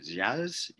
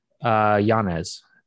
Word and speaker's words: Yanez: How do you say Adrian's last name? Yazes? – Yanez. Adrian Yanez.